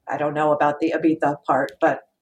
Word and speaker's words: Ibiza: I also think Judas Priest had spent a year out of their minds on drugs and booze in Ibiza trying to make a commercially successful follow-up at a funny time in their career.